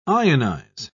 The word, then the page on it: ionise: British English